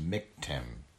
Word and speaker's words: Miktam: Miktam of David, pronounced Miktim.